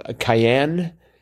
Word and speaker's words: cayenne: But I was unfamiliar with andouille, had only a passing knowledge of cayenne. Is that how it's pronounced, cayenne? – Cayenne pepper. – Okay. – That's how I always say it.